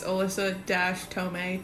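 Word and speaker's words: Allisa Thome: I'm pretty sure it's Allisa-Tome. T-H-O-M-E.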